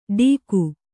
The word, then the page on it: ಢೀಗು: kannada